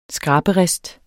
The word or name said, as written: skraberist